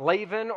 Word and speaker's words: Laban: So after Jacob initially runs from his family, he eventually goes to live with his mom's brother, his uncle Laban, is how it would be pronounced, Laban, or we read it L-A-B-A-N, Laban.